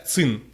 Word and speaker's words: Qin: Я почти уверен, что это читается ЦИН. ЦИН F21 Pro. QIN пишется.